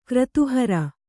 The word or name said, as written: ಕ್ರತುಹರ